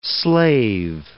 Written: slave

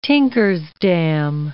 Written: tinker's damn

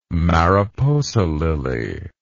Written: mariposa lily

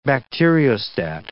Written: bacteriostat

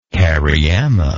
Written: cariama

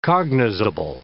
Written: cognizable

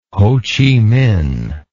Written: Ho Chi Minh

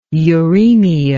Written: uraemia

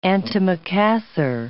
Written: antimacassar

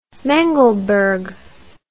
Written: mengelberg

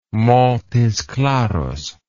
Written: Montes Claros

